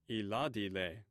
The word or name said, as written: ithadi